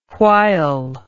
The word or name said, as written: whyll